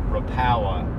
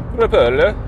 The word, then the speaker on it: Rapala: It really is, and another minor thing in our regional pronunciations is I say rapala for the Finnish, minnow lure, and that uses rapala. – And really, it should be rapala.